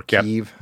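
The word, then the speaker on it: Kyiv: I've got a lot of colleagues in Ukraine, and especially in Kiev. – Uh-huh. Or Kiev, as they pronounce it.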